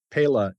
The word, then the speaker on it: PELA: He's the chairman of PELA, which is pronounced a number of different ways, but PELA is the Spanish for peel, which of course is what you put in Loamy.